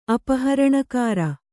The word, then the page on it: ಅಪಹರಣಕಾರ: apaharaṇakāra